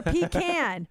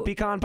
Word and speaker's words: pecan: Or pecan. – I say pecan. You want a pecan pie? – Wait, whoa, you say it with the emphasis on the P instead of the con.